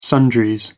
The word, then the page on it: sundries: American English